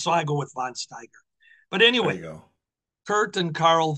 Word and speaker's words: Steiger: Don Owen had a tag team that he was working to get over, a couple of Germans, Kurt and Karl von Steiger. – Steiger. – I don't know how they pronounce it. I think it's Steiger, yeah. So. – S-T-I-E-G-E-R.